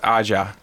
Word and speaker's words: Aja: No, Americanized would be Aja. – That's true. – It's yeah. – Aja. – Well, it's pronounced Aja. – Ah, gotcha. Okay. – I believe it's French.